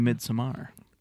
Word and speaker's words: Midsommar: I want to talk to you about Midsommar and get your thoughts on that. – Get into that. – On record. – Whoa. – Excuse me, Midsommar. – You can pronounce it both ways.